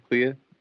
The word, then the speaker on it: Clea: Is that how you say it, Clea? – Yep, glad Clea. I was saying it right.